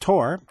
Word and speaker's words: Thor: Tor Hansen, it's spelled T-H-O-R, pronounced, so it's spelled like Thor, pronounced Tor, Tor Hansen.